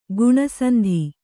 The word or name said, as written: ಗುಣಸಂಧಿ